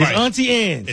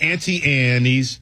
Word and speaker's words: Auntie Anne's: It's Auntie Anne's. – It's not Auntie Annie's, because Ant is an insect, Aunt is a person, and it's Anne's. The E is silent.